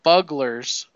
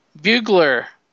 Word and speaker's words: bugler: and four bugglers. I don't know what a buggler is. Do you know what a buggler is? Bugler. Oh, what am I saying? Thank you, Barb. It's spelled like bugglers.